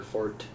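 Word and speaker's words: forte: Stealth has not historically been our fort. – Yeah. – Which apparently is how that's pronounced.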